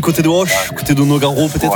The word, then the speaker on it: Nogaro: Nogaro.